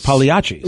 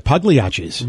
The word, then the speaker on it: Pagliacci: There's if you're from Italy, the G's are silent. – Oh, definitely. Like Pagliacci.